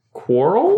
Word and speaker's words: Qorl: Oh, Q-O-R-L? – Yeah, Q-O-R-L. Quarrel? – Quarrel? – I think is probably the best way to pronounce that, but it is a little odd.